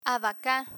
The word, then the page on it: abaca: Spanish